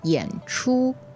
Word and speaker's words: 演出: So 演出, third tone, first tone means to perform. And how do you spell that? What's the pinyin? – Y-A-N, third tone, and C-H-U, first tone. 演出.